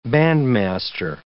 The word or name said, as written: bandmaster